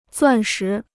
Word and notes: 钻石: zuàn shí

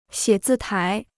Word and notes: 写字台: xiě zì tái

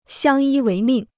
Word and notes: 相依为命: xiāng yī wéi mìng

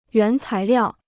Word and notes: 原材料: yuán cái liào

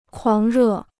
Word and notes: 狂热: kuáng rè

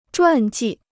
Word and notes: 传记: zhuàn jì

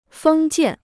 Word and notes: 封建: fēng jiàn

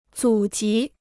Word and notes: 祖籍: zǔ jí

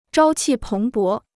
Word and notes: 朝气蓬勃: zhāo qì péng bó

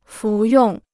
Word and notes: 服用: fú yòng

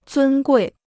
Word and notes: 尊贵: zūn guì

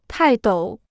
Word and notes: 泰斗: tài dǒu

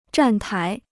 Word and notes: 站台: zhàn tái

